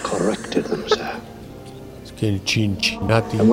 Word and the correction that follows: Cincinnati: No, sigue, pero Cincinnati no te puedo.